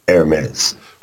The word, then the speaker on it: Hermes: But when you go look it up anywhere, including the, the AP Associated Press pronunciation guide, it's Hermes.